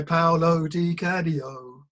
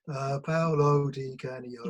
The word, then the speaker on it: Paolo Di Canio: But Paolo Di Canio. Paolo Di Canio.